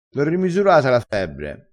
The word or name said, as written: strasciino